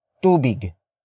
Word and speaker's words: tubig: And that is tubig. Tubig. Tubig. Tubig is spelled as T-U-B-I-G.